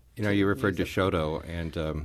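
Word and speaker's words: Chouteau: I was listening to St. Louis on the Air yesterday, and Don Marsh's guest was Jim Kircher, and they were talking about the real pronunciation is, I think they were saying Choteau. – Choteau.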